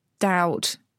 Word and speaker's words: doubt: So doubt, B, they stuck that in because the Latin dubitum.